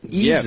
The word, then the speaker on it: Isaias: Now, they pronounce it Isaias.